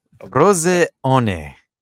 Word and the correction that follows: Frosinone: Frosinone, Jesus, it's not hard. Frosinone. – Why do I keep forgetting the N? I always forget the N in the middle, but we call them Juventus B because they have three of our starlets.